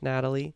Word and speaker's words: Nathalie: We also have Forrest Whitaker, John Voight, Aubrey Plaza, Lawrence Fishburne, Shia LaBeouf, Talia Shire, Jason Schwartzman, and Natalie Emmanuel, it's spelled Nathalie with a TH, but I believe that's probably pronounced Natalie.